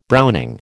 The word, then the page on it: browning: American English